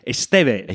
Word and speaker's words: Estevez: Apparently, I pronounced it Estevez, but the Spanish pronunciation is Estevez.